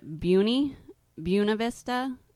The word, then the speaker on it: Buena Vista: Now, the locals pronounce it differently, right? – They call it Buena Vista, or we like to call it BV.